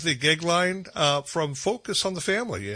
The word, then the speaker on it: Goeglein: It's Timothy, and his last name's Gegline, and it's spelled G-O-E-G-L-E-I-N.